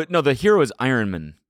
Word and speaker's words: Iron Man: You just really ethically react to something, then go off and have a kid, and then and go run an Iron Man, which nobody knows what that is or cares. – It's a weird. You put the emphasis on the wrong part of the Iron Man.